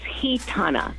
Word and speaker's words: Gitana: It's actually pronounced Gitana because that's a Spanish name. – Ooh. – So it's an H. Gitana.